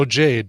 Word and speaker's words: Jade: Is it Jay or Jade? – Oh, Jade. Did I say Jay? Jade is right there under Kung-Lau.